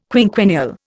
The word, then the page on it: quinquennial: Indian